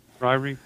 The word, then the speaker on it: Ryrie: Ryrie.